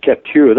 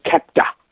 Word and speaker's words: Captur: It's interesting you pronounce it Captur. That's the way I pronounce it too. We both obviously have some French background. The local pronunciation, of course, is Australianised fairly severely to Captur. – Hmm. – So almost like K-A-P-T-A.